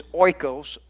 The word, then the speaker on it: oikos: And it's oikos. O-I-K-O-S is pronounced oikos.